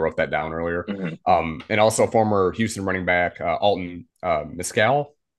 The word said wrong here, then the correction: McCaskill: I may have said that wrong. – McCaskill.